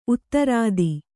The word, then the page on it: ಉತ್ತರಾಧಿ: kannada